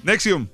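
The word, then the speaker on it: NXIVM: ¿cómo se dice? Nixum. – Nexium. ¿O ¿Cómo se llama? – Nexium. Nexium.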